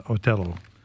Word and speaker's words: Otello: Yeah, just a quick, the Vashon Opera is putting on Otello, as the Italian pronunciation is.